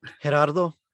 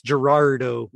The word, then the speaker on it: Gerardo: Gerardo. – So you are Geraldo. – No, it's Gerardo with an R-D-O, not L-D-O.